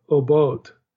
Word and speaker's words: Oboth: And one is the Old Testament place name Oboth. That's O-B-O-T-H.